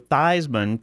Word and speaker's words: Theismann: How about changing the way you pronounce your name to Joe Theismann to rhyme with Heisman?